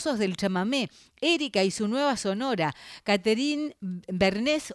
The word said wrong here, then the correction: Bernes: o Bernés, ¿cómo se dice? ¿Bernés? Bernes, siempre me equivoco.